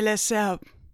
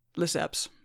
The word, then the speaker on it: Lesseps: With the Countess Luanne de Lesseps. Lesseps. Sorry. I should probably say her name right, too.